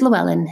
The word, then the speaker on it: Llewellyn: Do you want to say that? Llewellyn? – Oh, it's Llewellyn. Yes.